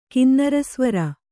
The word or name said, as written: ಕಿನ್ನರಸ್ವರ